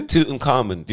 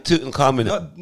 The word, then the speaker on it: Tutankhamun: Tutankhamun. Do you know who that is? – Say it again. – Tutankhamun. – Not Tutankhamun. Say it properly.